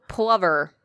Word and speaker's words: plover: Plover. Plover is how you pronounce it.